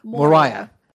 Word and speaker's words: Moira: It's not Moira. – Moriah. – Moriah, sorry, something like that, who knows.